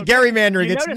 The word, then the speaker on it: gerrymandering: Because my wife is a direct descendant of Elbridge Gerry. And that's how you pronounce it. It's actually gerrymandering. Gerrymandering.